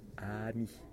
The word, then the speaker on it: ami: Okay, it's ami.